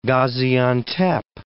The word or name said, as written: Gaziantep